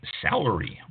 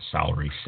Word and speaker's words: celery: National Fresh Celery Month. That's celery, not salary, celery. Let me pronounce that right. Celery.